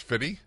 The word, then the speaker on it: 50 Cent: So I pronounced it incorrectly? – Yeah. – So it's Fitty? Did you know that? – No.